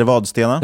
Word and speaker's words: Vadstena: Uttalar jag Vadstena rätt? Eller heter det Vadstena? – Det, heter Vadstena.